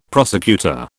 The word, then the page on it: prosecutor: British English